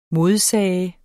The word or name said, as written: modsige